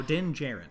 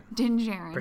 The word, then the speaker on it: Djarin: Oh, Din Djarin. – Din Djarin. – Pretend the second D isn't there.